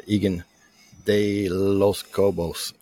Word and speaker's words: Egan de los Cobos: Today, we have a special guest on, Egan de los Cobos.